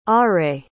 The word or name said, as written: areille